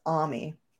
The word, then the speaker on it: Ami: It's a slightly different pronunciation because in Japanese it's Ami.